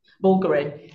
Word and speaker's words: Bulgari: So I don't know how to pronounce it. Bulgari. – Bulgari. It's not the same with a northern accent, is it?